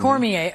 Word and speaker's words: Cormier: Cormier. Mm-hmm. – Cormier.